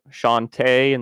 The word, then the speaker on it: Shantae: Did the soundtrack for, I'm going to pronounce this wrong and I know it, Shantae and the Seven Sirens. – Oh, yeah. – No, you got it.